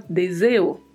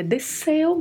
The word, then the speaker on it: deseo: Como já comentamos em outras palavras, em outros episódios, o S entre duas vogais no espanhol não tem som de Z. E às vezes os hablantes de português acabam escorregando aí na pronúncia e fala deseo. E não é deseo, é deseo mesmo, deseo.